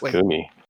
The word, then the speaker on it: Gumi: Wait, then you it just go Gumi? I go Gummy. – Oh, it's Gumi. – It has to be Gumi.